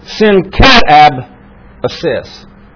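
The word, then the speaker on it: synkatabasis: S-Y-N-C-A-T-A-B-A-S-I-S. So there you have it, end it. And here is the accent right here. Syncatab assist. That's the way you pronounce it in order to get that. That's why it's so hard to pronounce, because the accent is in a strange place here. But when you look at this, let's break this word down. The S-Y-N means together.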